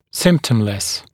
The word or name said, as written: symptomless